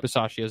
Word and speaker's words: Bisaccia: Bisaccia has done.